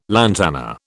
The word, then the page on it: lantana: British English